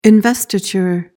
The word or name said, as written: investiture